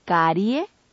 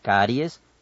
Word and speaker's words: caries: No digamos carie, ni cafeses, ni cónyuge. – Digamos caries, cafés, cónyuge.